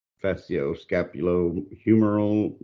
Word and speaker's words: facioscapulohumeral: Back up and explain to us what facioscapulohumeral muscular dystrophy.